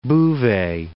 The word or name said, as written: Bouvet